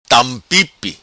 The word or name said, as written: Tampipi